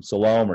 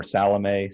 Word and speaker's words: Salome: You've got the women, Mary Magdalene, Mary the mother of James, and Salome, or Salome, some pronounce that.